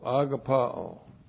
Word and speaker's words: agapao: A, G, A, P, A, long O, agapao.